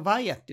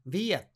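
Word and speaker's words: via: ちなみにこのバイアっていう前置ですね。ヴィアっていう発音としてはバイアでもヴィアでもね、どっちでも OK なので、それも覚えておきましょう。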